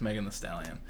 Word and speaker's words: Megan Thee Stallion: But it's just Megan Thee Stallion.